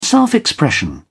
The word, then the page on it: self-expression: British English